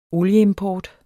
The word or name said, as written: olieimport